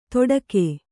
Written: ತೊಡಕೆ

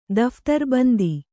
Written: ದಫ್ತರ್‍ಬಂದಿ

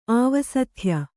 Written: ಆವಸಥ್ಯ

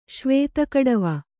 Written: ಶ್ವೇತಕಡವ

